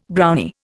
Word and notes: Brownie: Indian

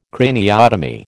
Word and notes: craniotomy: American English

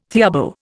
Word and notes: theorbo: Indian

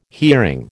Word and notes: hearing: American English

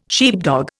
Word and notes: sheepdog: Indian

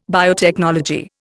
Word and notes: biotechnology: Indian

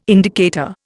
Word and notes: indicator: Indian

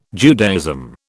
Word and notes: Judaism: British English

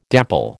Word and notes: dapple: American English

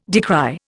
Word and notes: decry: Indian

